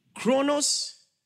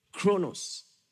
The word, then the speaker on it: chronos: The first, chronos, and the second, kairos. Chronos is spelled C-H-R-O-N-O-S. Kairos is spelled K-A-I-R-O-S.